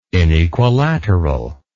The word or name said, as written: inequilateral